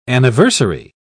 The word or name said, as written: anniversary